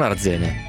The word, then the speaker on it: Arzene: E poi invece sono Henry, sono di Arzene con l'accento sulla A. Ah, quindi è Arzene, non Arzene.